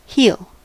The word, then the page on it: heal: [hil]; American English